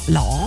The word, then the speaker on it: Lau: Der Joe in der Oststeiermark ist es der Lau. Er schreibt nasaler ausgesprochen als bei lauwarm, also Lau.